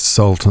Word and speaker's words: Sowt: Even though it's spelled S-O-W-T, the pronunciation is salt, and the music is music.